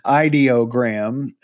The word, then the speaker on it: Ideogram: it's Ideogram is how you say that.